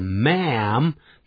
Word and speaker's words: ma'am: 念的时候要念成 ma'am 跟男人那个念法它的元音很像 M-A-N M-A-N